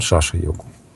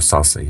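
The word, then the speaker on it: шаша-йога: Марс дает ручака-йогу, Меркурий дает бадра-йогу, Юпитер дает хамса-йогу, Венера дает малавия-йогу и Сатурн – шаша-йогу. Или ее саса иногда называют, потому что так произносят индусы.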